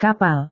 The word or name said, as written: kapal